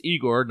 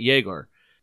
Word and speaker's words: Egor: First off, he says it's Igor, and he said that pretty forcefully. It's Igor, not Yegor,